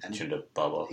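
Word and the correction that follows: Ponda Baba: I think it's Punda Baba. – Punda Baba.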